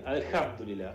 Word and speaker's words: Alhamdulillah: Alhamdulillah. Mirá, yo tengo que ir a Qatar y voy a estar medio complicado. – Estábamos casi bien. Alhamdulillah, acentuando la segunda. – Alhamdulillah.